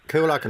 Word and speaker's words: Coolock: Coolock, is that how you pronounce it? Coolock? – Coolock in Dublin, yeah.